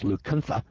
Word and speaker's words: Lacunza: Well, in this book, Lacunza, which is pronounced Lucunza,